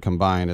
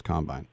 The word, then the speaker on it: combine: And remember, in the NFL, the emphasis is on the second syllable. It's not offense, defense, and combine. It's offense, defense, combine.